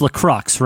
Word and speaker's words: LaCroix: It's La Crux, right? That's how you say it, Annie? La Crux? – Yeah. I said it incorrectly. Thank you.